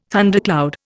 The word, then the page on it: thundercloud: Indian